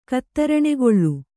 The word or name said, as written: ಕತ್ತರಣೆಗೊಳ್ಳು